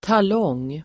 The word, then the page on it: talong: [tal'ång:]